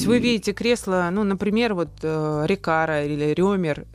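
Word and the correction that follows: Römer: Ремер? Правильно говорю? – Да, Рю, рюмер. Бритекс Рю... рюмер, если вот совсем правильно.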